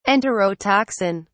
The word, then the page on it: enterotoxin: British English